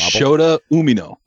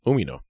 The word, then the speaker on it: Shota Umino: Shota Umino. – Close. – Ah, fuck. – You're close. Accents in the wrong spot. – In the wrong syllable. – Shota Umino. – Umino.